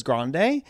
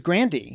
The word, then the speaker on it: Grande: So I changed the pronunciation of her last name. That's why everyone says Grande. – Because Grande. – It was Grandy. – Grandy. – Yeah, I was raised Frankie Grandy.